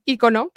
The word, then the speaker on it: icono: Pero bueno, también se puede decir ícono, porque en español se le pone el acento en la I, la diéresis.